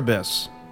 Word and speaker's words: Cerberus: Is that how you pronounce that? – Oh, Cerberus. Yeah.